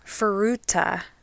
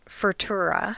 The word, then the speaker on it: Furuta: So first, Junko's last name is Furuta, not Furtura.